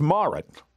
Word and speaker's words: Marit: am I saying it right, Marit Stiles? – Yeah, you are. Yep, that's right. – Okay, so it's Marit.